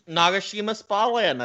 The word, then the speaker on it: Nagashima Spa Land: I'd probably say either like some of the Japanese parks, like Nagashima Spa Land. I'm sure I'm pronouncing that wrong. – Nope, that's actually correct. – Yeah? – Nagashima Spa Land, that's correct.